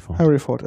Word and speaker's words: Hereford: Harry Ford. – Oh ja, genau. Harry Ford. – Ja, das ist die Aussprache. Englisch ist ja mal schwierig. Aber es ist Harry Ford.